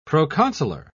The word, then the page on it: proconsular: British English